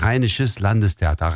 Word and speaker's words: Rheinisches Landestheater: Ardından benim için telaffuzu zor olan Rheinisches Landestheater söyler misiniz? – Evet, Rheinisches Landestheater.